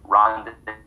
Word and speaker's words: rendezvous: It's not spelled like it sounds. RENDEZVOUS. Rendezvous.